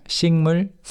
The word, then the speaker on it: 식물: Let's talk about the pronunciation first. 식물. – 식물. – But you say 식물. – 식물. – 싱, 싱, 싱. – With the 이응 받침.